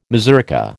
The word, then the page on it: mazurka: American English